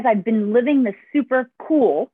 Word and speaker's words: Kuel: Now, Jack is the owner of a company called Cool Life, which is actually kind of cool, the name, because it's spelled K-U-E-L.